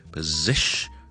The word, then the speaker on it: position: 而第一个音节咧，我哋唔系读 po 嘅，p p p，跟住后面 second syllable stress it position position position，跟住 shun 就得啦，position position position position。